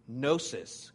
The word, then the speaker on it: gnosis: It's gnosis, gnosis. The G is silent, though. You can say it if you want, though. Gnosis.